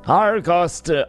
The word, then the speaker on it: Argynvostholt: Argynvostholt.